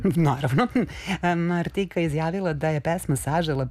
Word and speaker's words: Martika: Vidiš koju je Martika ili Martika sad, i tu je pitanje kako pravilno da izgovorimo. Gledala sam intervjue, ona samu sebe zove Martika, ok.